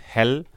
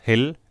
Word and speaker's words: Hell: Ähm, und zwar die Ärzte mit Hell oder Hell, je nachdem, ob man Englisch oder Deutsch aussprechen will.